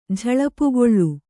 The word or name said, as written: ಝಳಪುಗೊಳ್ಳು